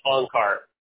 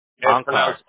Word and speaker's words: Onkar: It's O-N-K-A-R. – O-N-K-L-A-R? Or? – No. O-N-K-A-R. And it's pronounced Ankar. Ankar.